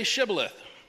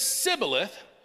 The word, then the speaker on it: Shibboleth: It says, they said, all right, then say Shibboleth. And if he said Sibboleth, so we're missing something there, because he could not pronounce the word correctly, they seized him and killed him at the fords of the Jordan.